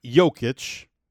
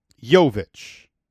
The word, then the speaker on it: Jovic: Not Jokic, Jovic.